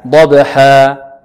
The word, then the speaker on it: ضبح: ولذلك هكذا ضبحا، ليس ضبحا ضبحا، خطأ هكذا. والصحيح ماذا؟ ضبحا حا، واضح بإذن الله. ضبح، إذن نفخم الضاد ونوقق الباء ونقلقلها.